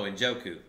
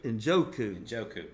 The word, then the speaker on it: Njoku: And I like Nkoju. Did I say his name right? David Nkoju from Cleveland. – Oh, Njoku. – Njoku, yeah.